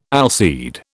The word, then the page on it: allseed: British English